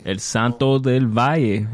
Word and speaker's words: El Santo del Valle: El Santo del Valle.